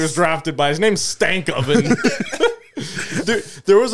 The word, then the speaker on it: Stankoven: It's Stankoven. That's Stankoven. – It's spelled Stankoven.